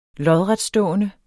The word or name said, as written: lodretstående